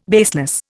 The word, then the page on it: baseless: Indian